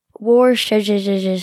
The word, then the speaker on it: Worcestershire: And then if you poured, like, Worcestershire sauce.